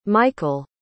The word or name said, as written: micheal